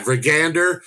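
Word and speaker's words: Vikander: Vigander? – Yeah, Vigander.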